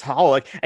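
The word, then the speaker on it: xxxHOLiC: And then there's like X, it's spelled XXX Holic, but you're supposed to just pronounce Holic.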